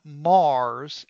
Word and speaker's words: Meagher: Guitar player Ryan Mars, and he says that's the way you pronounce his name is Mar, but it's spelled M-E-A-G-H-E-R.